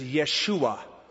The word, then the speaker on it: Yeshua: Joshua is the English pronunciation. The Hebrew pronunciation is Yeshua. It's the name of Jesus, Yeshua. It's really a contraction of the Hebrew word Yahoshua, which is Yahweh saves. The contraction Yeshua, or as I pronounce it as it is written, Joshua.